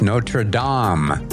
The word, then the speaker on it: Notre Dame: Now, I said Notre Dame. It's not Notre Dame. It's Notre Dame. Notre Dame.